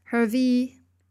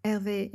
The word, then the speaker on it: Hervé: Yeah, here comes my favorite part of the tour with Hervé, Hervé in French. I pronounce it in an English way because you know I want you to understand.